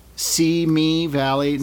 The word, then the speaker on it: Simi Valley: I don't want to start by correcting pronunciations and stuff, but just so you know, it's Simi Valley. Simi Valley, not Simi.